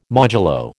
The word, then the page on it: modulo: American English